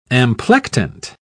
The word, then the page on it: amplectant: British English